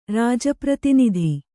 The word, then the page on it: ರಾಜಪ್ರತಿನಿಧಿ: rāja pratinidhi